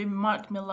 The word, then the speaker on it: Millar: But Mark Miller is a comic guy that we know these days. – Mark Miller would be Mark Millar, I think that's how he pronounces his name.